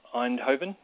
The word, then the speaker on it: Eindhoven: and it will be in Eindhoven. Is that the right pronunciation? – Yes.